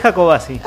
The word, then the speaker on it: Jacobacci: Sí, es Jacobacci.